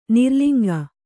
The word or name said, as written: ನಿರ್ಲಿಂಗ